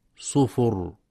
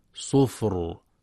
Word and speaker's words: صفر: ويخطئ من يقرأ بضم الفاء في كلمة صفر فيقرأ خطأ صفر، والصواب سكونها صفر مع مراعاة تفخيم الراء.